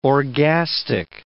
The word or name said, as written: orgastic